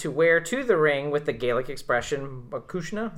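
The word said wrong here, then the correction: mo chuisle: is that how you say it? Makushla, embroidered on the back, but will not tell her what it means.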